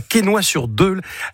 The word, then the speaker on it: Quesnoy-sur-Deûle: Tu habites à Quesnoy-sur-Deûle. Est-ce que je l'ai bien prononcé ? J'ai pas écorché le nom ? – Impeccable.